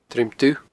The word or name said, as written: Druim Dubh